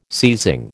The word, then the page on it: seizing: American English